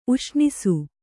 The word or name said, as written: ಉಷ್ಣಿಸು